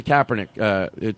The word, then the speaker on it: Kaepernick: So with I had a thought on the Kaepernick taking the knee, you know? – Mm-hmm.